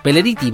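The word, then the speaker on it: Pelleritti: Marcelo Peleretti, Peleriti, perdón, Vicentico, Darío Schwansteiger, no mentira, es Darío Steins Steinschreiber, ahí está.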